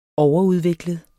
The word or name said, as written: overudviklet